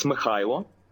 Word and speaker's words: Mykhailo: Anyway, so Mikhailo is how we would say it. That's how it's spelled in English alphabet. – Yeah. – But how do you say it properly? – Well, in Ukrainian, it's Mikhailo. Uh-huh. So it's like you just get less of a K in it. It just go huh, like with Kherson.